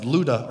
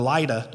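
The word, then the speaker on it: Lydda: He's in a place called Luda, or Lida, depending on how you pronounce it, about 10 miles from the coast near Tel Aviv, where Tel Aviv would be today.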